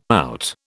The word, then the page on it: out: British English